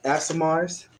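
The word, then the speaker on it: Aasimar: So if that is the case, then I would say I've been reading that book that I got about Asimars and stuff that you... – I believe it's pronounced Asimar.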